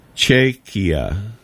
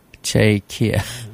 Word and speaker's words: Czechia: So Tracy has put in a pronunciation guide here. Chaikia. – Chaikia. – C-Z-E-C-H-I-A.